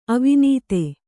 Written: ಅವಿನೀತ

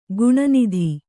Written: ಖಿನ್ನತೆ

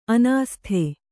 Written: ಅನಾಸ್ಥೆ

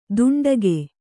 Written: ದುಂಡಗೆ

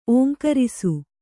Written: ಓಂಕರಿಸು

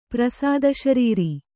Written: ಪ್ರಸಾದಶರೀರಿ

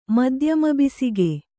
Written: ಮಧ್ಯಮಬಿಸಿಗೆ

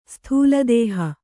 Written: ಸ್ಥೂಲದೇಹಿ

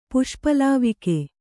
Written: ಪುಷ್ಪಲಾವಕ

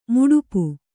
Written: ಮಡಿಪು

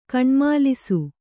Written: ಕಣ್ಮಾಲಿಸು

